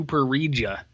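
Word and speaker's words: pareja: Yeah, you got to learn how to say the Spanish here. Let me hit you. Solo con tu Parija. – Parija. – That's how you say this.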